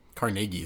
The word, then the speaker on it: Carnegie: Carnegie or Carnegie, how do you say it? – Carnegie is how he said it.